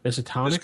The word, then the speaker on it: Miskatonic: How do you pronounce that? – Miskatonic.